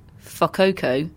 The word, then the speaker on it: Fukuoka: ITF titles for Katie Bolter in a place I mispronounced earlier, but I'm going to confidently call Fukuoka.